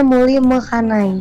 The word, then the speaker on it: Muli Mekanai: Aku pengen nanya soal juara runner up 3 Muli Mekanai, bener ya? – Iya Kak. – Dibacanya betul ya? – Dibacanya Muli Mekanai. – Mekanai? – Iya.